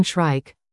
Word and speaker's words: shrike: Field guides will have it indexed under its more common name, Northern Shrike, rhymes with strike.